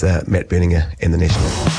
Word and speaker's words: Berninger: And I did look up how to pronounce Matt Berninger because there's always been for some time a debate whether it's Matt Berninger or Berninger. And he said that he answers to all but prefers the soft G, which I think makes it Matt Berninger.